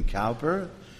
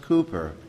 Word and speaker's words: Cowper: You know, I'd look at that name and I'd see C-O-W. I'm thinking, Cowper? No, it's pronounced Cowper.